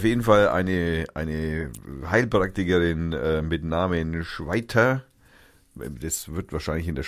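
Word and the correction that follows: Schwyter: Schweiz auch irgendwie merkwürdig ausgesprochen, weil S-C-H-W-Y. Wie spricht man das? Schwitter? Schwitter. Schwitter. Ja, die Frau.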